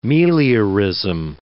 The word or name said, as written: meliorism